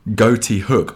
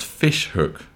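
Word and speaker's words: Ghoti Hook: The first song is by a band called Goatee Hook, although I'm reliably told that it actually is pronounced Fish Hook, because goatee, as it's spelled, is the phonetic spelling of fish.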